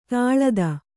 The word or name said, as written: ತಳೆತ